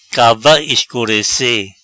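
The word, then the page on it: Cava escurece: Patua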